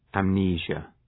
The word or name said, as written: amnesia